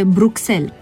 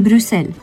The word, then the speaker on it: Bruxelles: Eu am auzit spunându-se Bruxelles, capitala Belgiei. Este o eroare, corect este cu U și cu S și cu accentul pe E, Bruxelles.